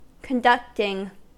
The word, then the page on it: conducting: American English